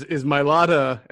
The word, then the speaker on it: Mailata: So you're asking, is it my Lotta or is it Jack Driscoll? – Correct. – So the real question is Mailata, I'm finally saying that correctly now.